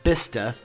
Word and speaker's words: Bicester: Bicester is pronounced Bister.